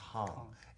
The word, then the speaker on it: hang: It's called hang. It's spelled hang, like in English, you know, just hang. But it's in Swiss German, it's pronounced hang.